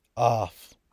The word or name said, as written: aaf